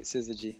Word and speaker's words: syzygy: Yep, that's right, Syzygy.